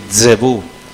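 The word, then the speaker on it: Zebub: Zebud, la pronunciación es exagerada. El judío dice Zebú, porque hace el sonido de la mosca.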